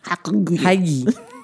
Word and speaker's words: hygge: Because I believe the way it's pronounced is misleading. – It's H-Y-G-G-E, which to me reads hygge. Hygge. – But it's hygge.